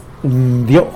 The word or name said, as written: ndyo